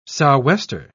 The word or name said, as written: sou'wester